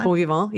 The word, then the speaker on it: bon vivant: Yes, pigs work hard, but the ox doesn't appreciate the pig's bon vivant. Is that how you say it? – Vivant? Bon vivant. Yeah, bon vivant.